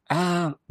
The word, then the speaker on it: lumpia: Now, is it pronounced Lumpia or Lumpia? – Lumpia, Lumpia. I believe, would be the correct way.